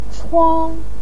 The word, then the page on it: chuang1: pinyin